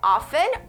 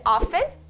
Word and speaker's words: often: E aqui a gente tem uma dica de pronúncia que você pode dizer often ou often. Eu prefiro dizer often, quite often, que significa com uma certa frequência, quite often.